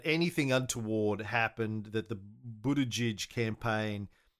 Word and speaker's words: Buttigieg: And then the news came out quite quickly after this big failure that Pete Sodomite Buttigieg.